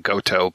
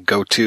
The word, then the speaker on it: Goto: If you don't know the small ICA cinema, I mean, um, an iPhone has a bigger screen of course, you're saying it correctly by saying Goto, but me having learned basic in seventh grade, I keep thinking Goto, you know, 10, Goto 20, 20, print, hello.